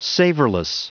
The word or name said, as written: savorless